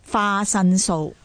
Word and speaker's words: 花神show: Yeah, and when you say fashion show, that is 花神show. – Oh, I didn't know that's from the fashion show. – Yeah, they you know, they kind of borrow the pronunciation from the word.